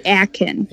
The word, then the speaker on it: Akin: Fatih Akin.